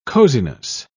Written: coziness

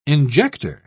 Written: injector

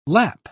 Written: lap

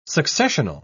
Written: successional

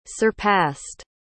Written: surpassed

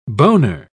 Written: boner

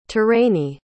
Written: terrany

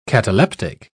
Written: cataleptic

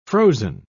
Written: frozen

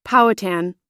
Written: powhatan

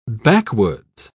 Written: backwoods

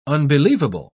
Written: unbelievable

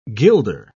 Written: guilder

